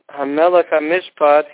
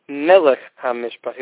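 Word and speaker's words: HaMelech HaMishpat (המלך המשפט): Instead of saying HaMelech HaMishpat, he said Melech HaMishpat. He left out the first hey. Melech HaMishpat, B'shnei Heyin, that's the correct way to say it.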